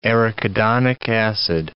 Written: arachidonic acid

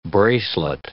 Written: bracelet

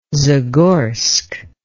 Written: zagorsk

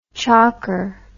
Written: chocker

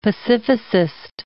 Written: pacificist

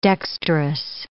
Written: dexterous

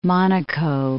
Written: Monaco

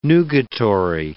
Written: nugatory

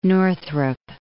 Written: Northrop

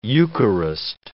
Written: Eucharist

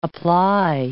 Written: apply

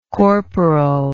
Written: corporalcy

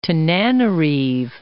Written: Tananarive

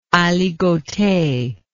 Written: aligote

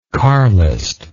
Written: carlism

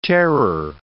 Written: terror

